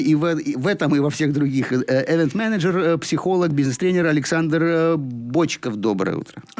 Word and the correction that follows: Бочков: Ну, я бы сказал, что я Бочков. – Бочков, да. Вот это вот, это ошибка, которую я допустил, прошу прощения, всегда уточняю ударение, а тут вот не попал.